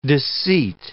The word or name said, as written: disseat